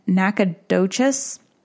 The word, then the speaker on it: Nacogdoches: It doesn't look like how it's supposed to be pronounced, but Nacogdoches. There's a G in there.